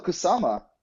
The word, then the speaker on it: Kusama: Kasuma Network? Is it? – Oh, Kusama.